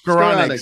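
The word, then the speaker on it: Skowronek: And then I went to Rams and I talked to Ben Scorette. God, I can't say his name. Ben Skoronek. – Skoronek, sorry. Thanks for the assist.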